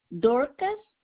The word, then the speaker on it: Dorcas: Dorcas. D-O-R-C-A-S Wilson. – You said Dorcas, D-O-R-C-A-S? – Yes, ma'am.